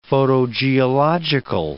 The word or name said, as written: photogeological